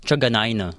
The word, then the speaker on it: Truganina: Ako, nung isang beses kasi, di ba dati na kapag trabaho ako, sabi ko, uh, Truganina.